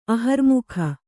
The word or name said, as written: ಆಹಾರಮುಖ